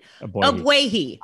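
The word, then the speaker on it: Ogbuehi: did I pronounce that wrong? Sorry. Help me out. You're the Abwehi. Radio. – Abwehi. The way Cedric Abwehi has played.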